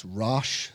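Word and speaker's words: Róis: That's Rosh, so that's spelled R-O-I-S.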